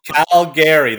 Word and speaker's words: Calgary: Calgary. That's how you say it. If you go anywhere, Calgary. You'll never be mocked if you say it that way.